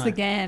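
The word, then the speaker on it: Ghan: Did I say it right? The Garn or the Gan? I think it's the Gan.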